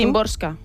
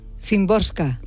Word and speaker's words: Szymborska: A ver, ¿cómo lo pronuncias tú? – Szymborska. – Szymborska.